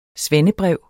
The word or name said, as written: svendebrev